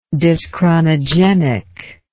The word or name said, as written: dyschronogenic